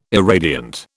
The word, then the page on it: irradiant: British English